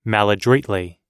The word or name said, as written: maladroitly